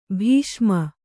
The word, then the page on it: ಬಸುಮ: basuma